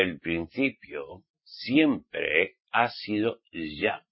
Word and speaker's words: Yahweh: Y pone mucho siempre YHWH, pero su nombre auténtico y verdadero es Yahweh. Esto téngalo bien en cuenta. Que aunque vean en las escrituras YHWH, es Yahweh.